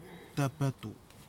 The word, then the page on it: tapatou: kwamera